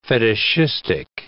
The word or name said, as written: fetishistic